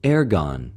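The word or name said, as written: ἐργον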